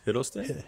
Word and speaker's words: Hiddleston: I think it's Hiddleston.